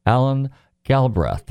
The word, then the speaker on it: Alan Galbraith: I'm delighted to welcome to the show this evening Alan Galbraith.